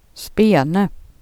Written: spenar

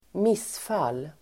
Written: missfall

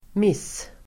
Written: miss-

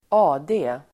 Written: AD

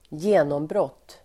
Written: genombrott

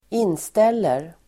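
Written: inställer

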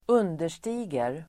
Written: understiger

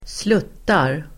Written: sluttar

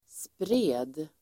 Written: spred

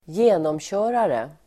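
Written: genomkörare

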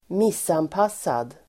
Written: missanpassad

